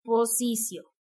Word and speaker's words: positio: y después él va a relatar un documento que se llama posicio, ¿ok? Se escribe positio, me parece que está en latín,